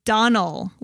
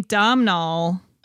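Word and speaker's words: Domhnall: It's Donald, like Donald with no D. – Oh, whoops. I've been saying it wrong for a very long time. – I've been saying Domnall.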